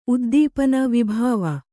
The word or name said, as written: ವೈಭವ